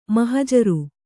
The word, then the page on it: ಮಹಜರು: mahajaru